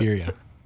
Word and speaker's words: birria: Birreria, is that right? – I couldn't either. It's so. – Birria. – If you roll your R's, just say it like a D. So B-D-A-D-A.